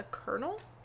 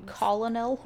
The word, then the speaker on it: colonel: A figure of a, fuck, I hate this word, a colonel? – Yeah. – The fucking word that doesn't have an R in it. And you colonel? – Yeah. – Colonel? – And you pronounce it colonel? – Yeah.